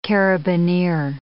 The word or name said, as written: carabineer